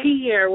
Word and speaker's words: Tierra: Tierra with a T as in Tom.